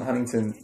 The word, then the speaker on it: Huntington: People from Huntington don't pronounce the T.